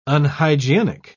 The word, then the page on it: unhygienic: British English